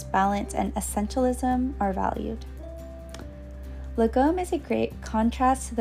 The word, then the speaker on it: lagom: Legom is spelled L-A-G-O-M and is a Swedish word that, similar to the Danish word hygge, does not have a direct English translation.